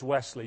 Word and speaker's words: Wesley: Now, my name is Wesley, W-E-S-L-E-Y. Now, I would be very upset if somebody were to spell it W-E-A-S-L-E-Y, Weasley, okay?